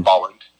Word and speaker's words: Bolland: Well, there's that thing in Brian Bollard's, am I saying his name right? Brian Bollard? – What, there's an N. – Bolland.